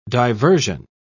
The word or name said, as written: diversion